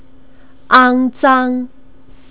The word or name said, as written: angzang